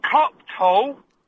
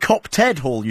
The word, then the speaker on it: Copped Hall: Copped Hall, right, because the man earlier said Copted Hall, you see. – No, it's Copped.